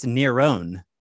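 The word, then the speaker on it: Neron (Νέρων): His name in Greek is said differently. It's Neron.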